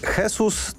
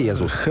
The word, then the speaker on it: Jesus: Czy Jesus to jest odpowiednia postać, która może godnie zastąpić Roberta Lewandowskiego? – No umówmy się, że Lewy to jest... – Dobrze wymawiam. Jesus, nie Jezus. Jesus.